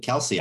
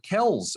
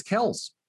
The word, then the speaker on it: Kelce: Oh, I've always pronounced it Kelce. I didn't know that was wrong. – Okay, no, he was in the news last week. He calls it Kels. Apparently it's Kels.